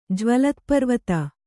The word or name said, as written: ಜ್ವಲತ್ಪರ್ವತ